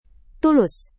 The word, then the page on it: tulus: Indonesian